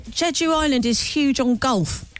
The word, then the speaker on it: Jeju: How do you spell that? – J-E... – Jeju Island. – J-U. – Oh, yeah, Jeju. I've got it.